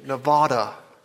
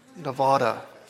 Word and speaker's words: Nevada: Y'all been saying Nevada wrong. And it's time to correct your pronunciation. Nevada. Nevada. Everybody knows in Arkansas that's how you say it.